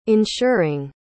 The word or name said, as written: insuring